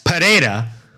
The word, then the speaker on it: Pereira: Alex Pereira,